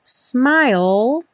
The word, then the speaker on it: smile: Smile sem pronunciar o E final. Essa palavra vai aparecer muitas vezes. Lembra que a gente termina o som dela com o L lá no céu da boca.